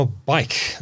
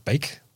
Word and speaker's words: BAIC: Now, bike. I think that's how you pronounce it. Not bake. – Bike. – Bike. – B-A-I-C. – B-A-I-C.